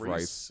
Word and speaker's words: Reis: I never knew how to pronounce Rice. I think it's Rice. – Yeah, R-E-I-S.